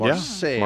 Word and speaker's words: Marseille: Is that how you pronounce it? – Mm-hmm. Marseille. Yeah.